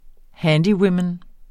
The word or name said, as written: handywoman